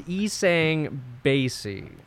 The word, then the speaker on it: Isang Bassey: He'll tell you it's Bassey, but it is Isang Bassey.